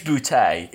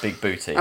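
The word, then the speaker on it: Bigbooté: There's a lot of conversation about how to say his surname. It is Booty. It's not Big Booty.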